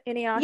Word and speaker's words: Inuyasha: Is it Inuyasha? – Is that how you pronounce it? Inuyasha? – Yeah, that's actually a, a, person that there's a bunch of demons, dude.